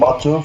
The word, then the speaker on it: Batu: Justin not available today because we're doing an international interview all the way to the country of Turkey with Batu Çetin. If I said that right, please forgive me if I mispronounced it.